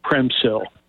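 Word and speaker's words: Przemyśl: And that's for Americans, that's pronounced, that's spelled like Premysyl. So it's like Premsil.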